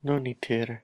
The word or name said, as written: lonitir